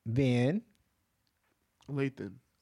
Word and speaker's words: Van Lathan: Get his name right. Van Lathan. – Lathan. – All right. You said you said Lan.